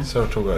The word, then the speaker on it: Saratoga: Saratoga ist es okay.